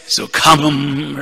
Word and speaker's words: sukham: Sukham, right. The ha has to be pronounced.